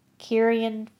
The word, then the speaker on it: Ciaran: Again, written and directed by Derrickson and Cargill, written by Derrickson and Cargill, this time directed by, Kieran Foy, and that's spelled C-I-A-R-A-N, starring James Ranson again.